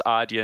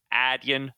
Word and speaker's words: Adyen: It is Adyen, and I was calling it Adyen.